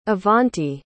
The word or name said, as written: avanti